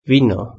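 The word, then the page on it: vino: Spanish (Spain)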